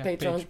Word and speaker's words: Patreon: Am I saying it right? – Yeah, Patreon. – Patreon. Yeah.